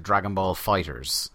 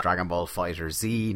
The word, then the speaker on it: Dragon Ball FighterZ: It is pronounced, in fact, Dragon Ball Fighters, not Dragon Ball FighterZ, not Dragon Ball Fighters or anything like that.